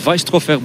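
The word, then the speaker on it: Veistroffer: Zo moet je het blijkbaar uitspreken. Weisstrofeur, Baptiste, Weisstrofeur. En dan kunnen we vooral toch echt gaan denken aan de tussenspurt.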